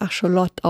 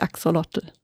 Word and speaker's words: Axolotl: In der Originalsprache wird Acholot ausgesprochen, also nicht Axolotl.